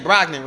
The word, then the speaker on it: Brogdon: Brogdon, right? – Yeah.